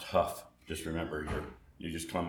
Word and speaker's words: Hough: So that's why it's spelled the way it is, with the H-O-U-G-H, Huff.